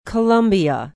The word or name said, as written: Columbia